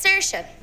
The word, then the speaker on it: Saoirse: It's Saoirse. S-A-O-I-R-S-E. – Saoirse? Ich habe Saoirse irgendwie im Kopf. Ich weiß es nicht genau. – Saoirse. – Ich hätte nochmal nachgucken sollen. Saoirse würde auf jeden Fall eher zu der Art und Weise passen, wie ihr Name geschrieben ist.